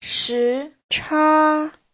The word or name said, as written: shi2cha1